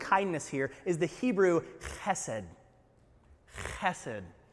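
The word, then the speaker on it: chesed (חסד): It's the hard H in Hebrew that you gotta love. So try, repeat after me chesed. There you go. If you don't taste your breakfast, you're not saying it right, right?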